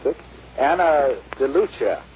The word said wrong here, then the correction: DeLuca: DeLuca. I thought two C's were ch. – No, it's C.